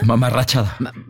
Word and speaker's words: mamarrachada: Luego dice, como dice Poncho, es una mamarruchada. – Mamarrachada. – Bueno, pues es lo mismo. ¿Viene el diccionario? ¿Cómo se dice? ¿Mamarrachada o mamarruchada? – Yo digo mamarrachada, pero es que no es que, no sé de qué manera esté bien dicho.